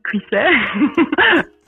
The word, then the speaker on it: Cuisset: My name is Fanny Cuisset. – Cuisset, okay. I knew I said it wrong earlier. Dang it. – Cuisset, and they can find me on, on Instagram.